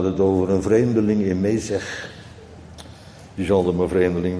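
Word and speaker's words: Mesech: Nou, dan beginnen we dus met Mezeg. Want zo willen ze dat wij het uitspreken.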